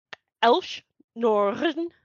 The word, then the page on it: Elesh Norn: Phyrexian